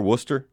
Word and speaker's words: Worcester: Now, first of all, is that how they pronounce it over there, Worcester? – Yeah. – Okay, so I'm saying it right.